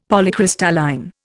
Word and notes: Polycrystalline: Indian

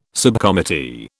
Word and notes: subcommittee: British English

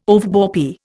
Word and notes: overbore: Indian

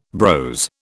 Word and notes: brose: British English

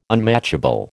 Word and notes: unmatchable: American English

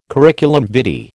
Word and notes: curriculum vitae: American English

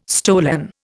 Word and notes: stolen: Indian

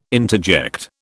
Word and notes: interject: British English

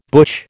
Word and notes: butch: American English

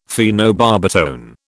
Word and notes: phenobarbitone: British English

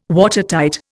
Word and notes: Watertight: Indian

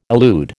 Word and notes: elude: American English

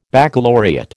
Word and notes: baccalaureate: American English

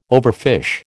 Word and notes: overfish: American English

